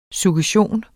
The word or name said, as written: suggestion